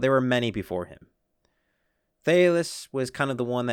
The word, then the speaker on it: Thales: Well, speaking of this Western philosophy and who started Western philosophy, many credit Thais Homiletius. Definitely did not pronounce that correct.